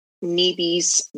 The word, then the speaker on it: Nibi: Okay, so you wrote the book, Nibi's Water Song. Did I say it right? – Yeah, Nibi. Yep.